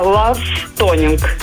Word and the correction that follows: Love Tonight: Ну, если правильно произношу. – Ну, почти правильно. «Love Tonight» называется она.